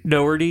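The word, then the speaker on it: Dougherty: You can tell that Dotary, is that how you say his name, the director? Is it Dotary or Daughtry? – I think it's Doherty. – Doherty? Yeah.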